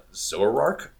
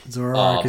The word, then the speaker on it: Zoroark: Zoroark? – Yeah. – Yeah. The crazy, like, fox Pokemon looks really, really, really cool. – Zoroark is my number two.